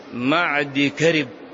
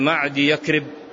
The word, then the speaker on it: معد كرب: يقولون ابن معد يكرب، وهذا خطأ، في النطق، بل هو المقداد ابن معد كرب.